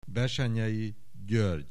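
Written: Bessenyei György

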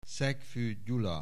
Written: Szekfue Gyula

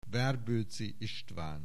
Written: Werboeczy Istvan

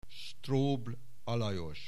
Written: Strobl Alajos

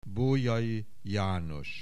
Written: Bolyai, János

